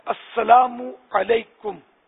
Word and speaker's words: السلام علیکم: نہیں، بہار شریعت میں لکھا ہے کہ السلام علیکم، یہ درست میم پر پیش ہو. اب اگر کسی نے کہا السلام علیکم تو جواب واجب نہیں ہے.